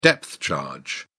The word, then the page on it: depth charge: British English